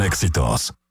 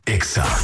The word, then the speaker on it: EXA: No se dicen éxitos. Se dice EXA. – En todas partes. – En todas partes. Ponte EXA FM. 89.9. – Escuchas. – Wake up en EXA FM.